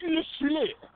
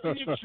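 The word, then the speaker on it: LaFleur: And Matt LeFleur. That's how you got to say his name. – Yeah, yeah, right. – Like Pepe Le Pew. Matt LeFleur.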